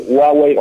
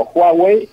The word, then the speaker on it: Huawei: Está aceptado de varias maneras, la, H como J o como muda, es decir, Huawei o Huawei, y también puede ser Huawei o eh, Huawei.